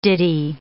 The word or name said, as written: ditty